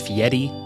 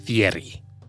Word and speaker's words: Fieri: It's pronounced Fieri, not Fieri.